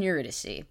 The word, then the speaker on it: Eurydice: So for now, Orpheus and Eurydice which is I did not know how to pronounce for a very long time. I thought I was like you're you're a you're a dice. You're dice.